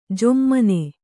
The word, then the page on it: ಝೊಮ್ಮನೆ: jhommane